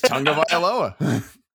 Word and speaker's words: Tagovailoa: It's Tagovailoa.